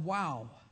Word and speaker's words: Wau: I think several years ago in the area that we live is called WOW. And when you get there, you'll think it's, man, wow. It's spelled W-A-U, and WOW is a very unique place.